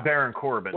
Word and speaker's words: Baron Corbin: Like, for instance, this his last final farewell match is against Corbin. Is that how you say his name? Yeah. – Baron Corbin.